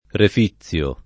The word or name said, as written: reficiare